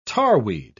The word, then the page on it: tarweed: British English